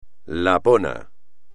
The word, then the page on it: lapón: espagnol